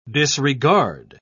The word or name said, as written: disregard